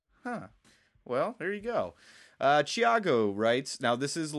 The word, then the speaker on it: Thiago: It's pronounced Thiago. Thiago.